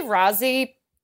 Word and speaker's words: Rozzi: No, some people say Rozzi and some people say Rozzi, and Rozzi. I don't. I say you know choose what makes you happy. – What do you say? – I say Rozzi just because that's what I've always said, but it probably is Rozzi, and it sounds nicer.